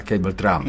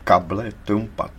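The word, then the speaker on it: cable trampa: To se vyslovuje možná cable Trump. – Ne, ne, ne, to se vyslovuje kable Trumpa. To je španělský termín značící nástražný drát k mině. – Aha. – Čili past, nástražnou past, kable Trumpa ve španělštině.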